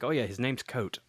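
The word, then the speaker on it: Kvothe: I'm like, okay, his name's Coat. But I had read the description beforehand, and it was like K-V-O-T-E. So I'm like, thank God someone pronounced this for me, because I'm going to be around going, I like Cavote. Cavote's a good character.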